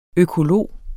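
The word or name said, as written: økolog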